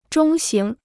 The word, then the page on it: 中型: zhōng xíng